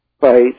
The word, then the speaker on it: Feith: I don't know how to pronounce his name. Fate or Fight, F-E-I-T-H. – Yeah. – Fight.